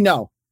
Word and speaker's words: Ngo: How do you say his last name? Ngo? – Andy No. – No, N-G-O.